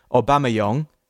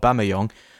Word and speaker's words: Aubameyang: I always surprise myself, by the way, how I say, because for about 15 years, no, not 15 years, for 10 years, I always said Aubameyang, as the French do, like the proper pronunciation of Aubameyang. And I can't believe I call him Aubameyang now, just because the English influence, which, you know.